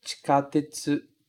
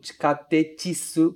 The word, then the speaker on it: chikatetsu: Aqui a gente tem dois cuidados para ter. O ti logo do começo, certo? Ti, pronunciado desta forma sempre. E o tsu lá no começo, para a gente não pronunciar tsu, ok? Chikatetsu. Cuidado para não colocar esse i no final. No tikatetsu, porque isso vai soar muito estranho.